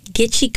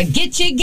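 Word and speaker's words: Gitchie: I'm covering the Gitche Girl and the murders at Gitche Manitou State Park.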